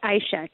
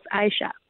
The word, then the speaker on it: Aisha: It's pronounced Aisha, two syllables, Aisha.